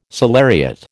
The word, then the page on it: salariat: American English